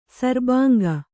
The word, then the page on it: ಶರಭಂಗ: Kannada